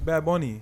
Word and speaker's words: Bad Bunny: And I love Bad Bunny, but it was mid. – Bad Bunny, say it right. – Bad Bunny. El Conejo Malo. – It's Bad Bunny. You got to say it with the accent.